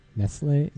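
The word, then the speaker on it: Nestlé: Although I've heard some evil shit about like Nestle or something or some Nestle? – Nestle? Is that how you pronounce it? – Yeah. – Nestle, fine.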